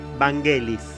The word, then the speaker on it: Vangelis: Según dicen, se pronuncia Vangelis.